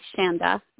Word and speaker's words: Chanda: It's C-H-A-N-D-A, Shanda, like panda, with a C-H.